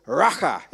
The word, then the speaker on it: Racha: Everyone say Racha. Racha. Racha. How do you say that? Racha. Racha. It's almost like you're spitting, isn't it? That's what it is. It's kind of this thing like I spit in your face.